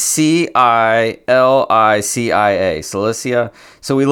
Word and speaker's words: Cilicia: With them they sent the following letter The apostles and elders, your brothers, to the Gentile believers in Antioch, Syria, and Cilicia Uh, all right, I'm going to stop Eric right there.